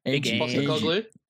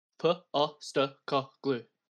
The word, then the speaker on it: Postecoglou: How do you say his name? – Postecoglou. – I don't know how to say his name, bro. Leave me alone. – Postecoglou.